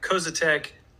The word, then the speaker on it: Koziatek: Koziatek totally nailed it.